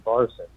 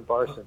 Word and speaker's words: Barson: You're making it a French pronunciation, but I am actually an anti-Zionist Jewish American from New Jersey. My name is Ben Barson, but Benjamin Barson. But I appreciate the intention behind the pronunciation there.